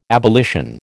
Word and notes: abolition: American English

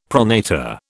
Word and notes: pronator: British English

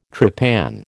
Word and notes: trepan: American English

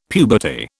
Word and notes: puberty: British English

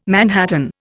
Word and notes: manhattan: Indian

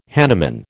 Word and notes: hanuman: American English

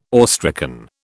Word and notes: awestricken: British English

